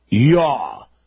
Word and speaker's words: Yah: Extol him that rideth upon the heavens by his name, Yah. It can't be spelled J-A-H, no J in the Hebrew language. It is spelled Y-A-H, Yahweh, Yah, and rejoice before him.